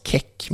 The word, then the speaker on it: Keck: Jag har läst Andreas Käck, har jag hittat en kille som heter på Aftonbladet eh, det kanske uttalas keck, men det är att det är... Stavas det som tjeck som i tjeckoslovak? Nej, K-E-C-K.